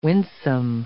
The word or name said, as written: winsome